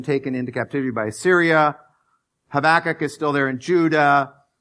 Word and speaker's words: Habakkuk: We pronounce it Habakkuk. But I learned later it's Habakkuk.